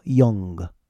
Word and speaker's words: Jung: Don't pronounce that J. Just go Jung.